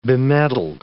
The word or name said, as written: bemedaled